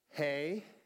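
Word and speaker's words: He: The next letter is pronounced Hey. Like, hey! That's what that one is, hey.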